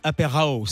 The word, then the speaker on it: Apér'HOW: Je le dis mal peut-être hein, avec mon accent anglais. Aperao, ça veut dire quoi Ça s'écrit comment alors, Aper A, oh, tu as très bien dit. Ça s'écrit A-P-E-R, comme le début d'apéro. Et ensuite H-O-W, comme how, comme comment.